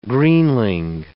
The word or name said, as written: greenling